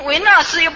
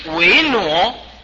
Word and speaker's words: 维纳斯: 人家又不怎么维纳斯，又不是雕塑像啊，什么维纳斯，要念维挪，这一定要念维挪，怎么念维纳呢？这个不懂发音呢，念着前台词念啊，你今天当维纳，他今天后天当木鱼。不是这样子，要念维挪，维挪，我们念一刹挪，一刹挪，是吧？